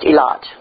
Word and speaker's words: Eilat: Is that the one that's spelled E-L-I-T-H? – T-H. It's pronounced Eilat.